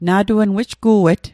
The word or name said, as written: na'tuen